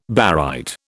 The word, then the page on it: barite: British English